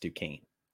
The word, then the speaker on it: Duquesne: And just in case anybody is wondering, we do know it's pronounced Duquesne.